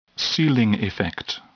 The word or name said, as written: ceiling effect